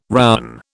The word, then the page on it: rowan: British English